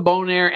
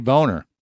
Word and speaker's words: Bonaire: I heard him say boner. – No, he said Bonaire.